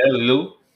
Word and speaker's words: Lille: Yeah, Lille.